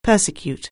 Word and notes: persecute: anglais

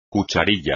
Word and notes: cucharilla: espagnol

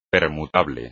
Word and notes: permutable: Spanish

